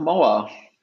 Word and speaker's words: MauAR: Er äh, spricht man die App MauAR aus tatsächlich? – Oder ich, wie würdest du sie äh, aussprechen? Ich nenne sie einfach Mauer.